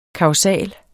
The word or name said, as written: kausal